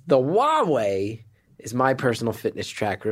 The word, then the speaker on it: Huawei: That is Huawei. – Huawei, yeah. – Keeping you active for all your favorite people. – Yeah. – Huawei. – Rhymes with Rahway, the town in New Jersey.